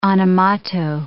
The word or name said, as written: animato